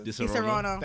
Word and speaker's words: Disaronno: Disaronno.